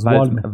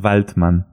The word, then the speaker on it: Waldmann: No es Waldman, es Waldman. – Waldman. En alemán es Waldman.